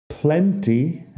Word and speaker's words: plenty: Plenty es algo que también pronuncio con la T suave. Si yo lo estoy diciendo, digo plenty. Plenty que hace rima con 20, que es el número de 20.